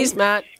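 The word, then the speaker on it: Keesmaat: I'm saying this because I've been listening to the calls and where your main opponent, who is Chrismet, is that how you pronounce her name? Kiesmat. – Yes, that's right, yeah.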